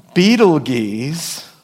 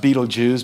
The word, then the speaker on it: Betelgeuse: Betelgeuse, pronounced Betelgeuse by many.